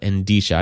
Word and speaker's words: Endicia: It is Endisha. A weird name. It is a weird name. Endisha. No. E-N-D-I. – I want to add syllables in there are. So. – Yeah. E-N-D-I-C-I-A. Endisha.